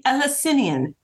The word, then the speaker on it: Eleusinian: the Eleusinian Mysteries. Is that right? – Eleusinian mysteries.